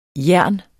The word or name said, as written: jern